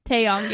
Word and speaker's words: Taeyong: Um, is it Taeyong? Is that how you say his name? Love Theory. Love Theory. Woo! I love that song. – yes, Taeyong. Yes, from NCT.